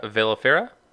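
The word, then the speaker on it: velifera: And so what's the status with your Pacilia vellifera? Vellifera? Am I pronouncing that right? – Yes, the vellifera, yeah.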